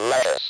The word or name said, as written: lettuce